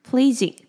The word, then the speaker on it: pleasing: pleasing，那 pleasing 这个字它这边 ea 是发长音的 e，跟前面的 pleasant ea 发 e 的短音是不一样的，所以发音的时候要小心。